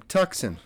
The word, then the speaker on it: Tucson: From Tucson. – Okay. Is that how you say it? – Yeah, it's science.